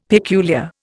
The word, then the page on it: peculiar: Indian